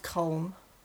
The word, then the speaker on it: Colm: It's pronounced Colm. – Colm? – Yeah, like Colm. Like Colin.